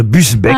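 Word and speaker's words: Busbecq: Ogier Gillin de Bubesque. – C'est vous dire que Benjamin Amran, qui habite Villeneuve d'Ornon, a une chance en Gironde de toucher un chèque RTL. Ogier Gillin de Busbeck, pardon, j'ai écorché son nom.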